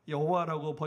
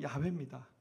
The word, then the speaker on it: 야훼: 그래서 우리가 여호와라고 번역했지만 사실 정확한 발음은 야훼입니다.